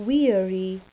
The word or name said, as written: weary